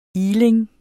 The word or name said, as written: iling